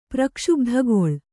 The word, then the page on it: ಪ್ರಕ್ಷುಬ್ಧಗೊಳ್: prakṣubdhagoḷ